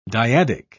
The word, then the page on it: dyadic: British English